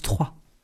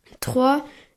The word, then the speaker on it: Troyes: En première place de la Ligue 2, il y a Troy. En deuxième place, Le Mans. Troisième place, Saint-Étienne. – Il y a Troyes. – Hein? – La ville se prononce Troyes. – Hein? – Voilà. – Troyes.